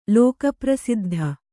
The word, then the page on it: ಲೋಕಪ್ರಸಿದ್ಧ: lōka prasiddha